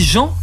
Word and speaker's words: Jehan: On dit Jean, mais sachez que ça s'écrit J-E-H-A-N, comme le prénom John en anglais.